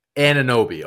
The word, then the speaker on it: Anunoby: Anunoby.